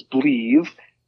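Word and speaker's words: Bleav: It's spelled a little interestingly. It's B-L-E-A-V. So it's Believe, in pronounced Believe, Believe in Chiefs.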